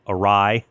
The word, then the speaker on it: awry: And that's why you'll find on air, I often mispronounce things or my pronunciation goes awry. I mean, awry. I had that one wrong for a really long time.